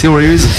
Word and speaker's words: Theories: Comment prononce-t-on Theories? Theories. – Theories. – Theories. – Avec la langue. – Ah, la thé, comme ça.